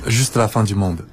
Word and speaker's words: Juste la fin du monde: Juste la fin du monde。 – 它就是你直译的对吗？法语应该是一个什么？ – 它的直译意思？ – 它差不多就是直译的意思啊，因为 juste la fin du monde 是世界末日， juste 在这里就是有这种，只不过就有一种不屑，有一种说也不就是个世界末日。